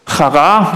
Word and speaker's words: Chara: Chara wird das ausgesprochen.